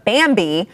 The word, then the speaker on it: Bambee: First, we want to thank our sponsor, Bambi. Now, that is spelled B-A-M-B-E-E because they fancy like that.